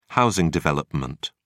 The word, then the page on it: housing development: British English